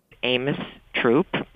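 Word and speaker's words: Throop: Amos Troop. – Hear that? Troop, which is how they say a bunch of stuff in Pasadena.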